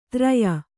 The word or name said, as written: ತ್ರಯ